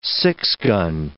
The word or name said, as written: six-gun